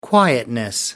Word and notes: quietness: British English

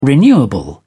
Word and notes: renewable: British English

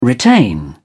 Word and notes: retain: British English

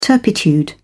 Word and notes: turpitude: British English

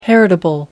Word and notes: heritable: American English

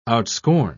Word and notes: outscorn: British English

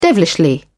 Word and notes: devilishly: British English

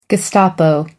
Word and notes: Gestapo: American English

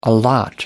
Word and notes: allot: American English

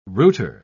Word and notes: rooter: British English

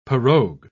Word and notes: pirogue: British English

